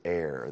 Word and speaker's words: heir: All right, that word heir. I'm going to give you another chance. Why don't you read verse 7, and that word that sounds like here is heir. The H is silent.